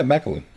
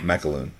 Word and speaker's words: McAloon: McAloon. – Yep. – Am I pronouncing that correctly? – Yeah, McAloon. – Okay. It's not Max, McAloon, right? It's not Max. – McAloon. Okay. No. – It's M-C-A-L. – Oh, yeah. – O-O-N.